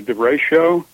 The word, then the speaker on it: derecho: It's called a derecho. It's spelled D-E-R-E-C-H-O. Derecho has a Spanish origin.